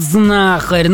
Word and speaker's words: знахарь: Думаю, ну, пусть скажет знахарь, ну, сын, чтобы... Да, совершенно верно. Данное слово происходит от глагола «знать», а потому ударение в нем ставится на первый слог.